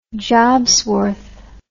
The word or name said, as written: jobsworth